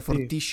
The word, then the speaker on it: Fortiche: È stata prodotta da Fortish Production, sono francesi, si scrive Fortiche però credo sia Fortish sì.